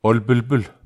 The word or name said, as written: ol pelpel